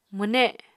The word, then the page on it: mwne: Apatani